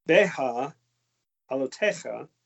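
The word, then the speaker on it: Beha'alotecha: It's Be'a Elotecha. Elotecha. – Mm-hmm. – Be'a Elotecha. I finally said it right. And Be'a Elotecha actually means when you set up.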